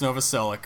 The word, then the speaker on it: Novoselic: Chris Novoselic.